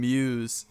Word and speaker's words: Mewes: I think George Carlin and Jason Mewes.